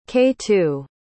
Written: k2

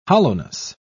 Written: hollowness

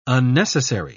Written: unnecessary

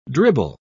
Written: dribble

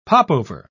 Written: popover

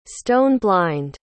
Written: stone-blind

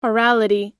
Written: orality

